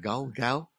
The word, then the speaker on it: gaal: G-A-A-L. How do you pronounce that? Gal, gal.